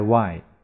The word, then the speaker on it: why: 注意 w h h 没有发音，我爱 why why not 为什么不呢？Tuesday 注意 s 要发浊音日 Tuesday Wednesday 星期三这个 e s 也要发日浊音 Wednesday Thursday 星期四 s 发浊音 Next 下一个的，其次的，week，周，星期，星期。